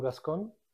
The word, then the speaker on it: Gascon: So, Cesar, it's pronounced in Romanian, Virtuoso, which is V-I-R-T-O-S-U. And the creative director, but I'm not, unfortunately. – So my name is Saul Gascon, G-A-S-C-O-N, and Saul, I better call Saul.